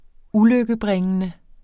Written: ulykkebringende

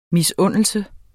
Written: misundelse

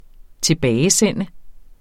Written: TILBAGESEND

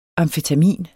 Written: AMFETAMIN

